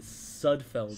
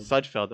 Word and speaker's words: Sudfeld: Nate Sudfeld. – Sudfeld, okay.